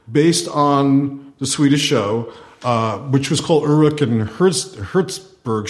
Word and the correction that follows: Ulveson och Herngren: Ulveson och Herngren.